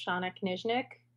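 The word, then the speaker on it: Shana Knizhnik: Both you and I have names that are constantly mispronounced, so I feel okay correcting you that it's Shayna Kaysnick.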